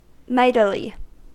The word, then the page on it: mightily: American English